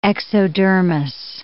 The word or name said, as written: exodermis